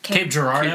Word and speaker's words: Cape Girardeau: Cape Girardeau. – Cape Girardeau.